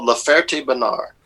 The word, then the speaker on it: La Ferté-Bernard: The town was called La Ferté-Bernard. Like Bernard, but you know how the French, they got to have something to not pronounce. So La Ferté Bernard.